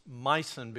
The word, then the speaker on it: Meissen: And I know that that's pronounced Meissen because I spoke to a German man here in town years ago who was from Germany, still had his German accent, and I said, would you please help me learn how to pronounce that? And it is Meissen, and this porcelain really is there, and he knew the city well.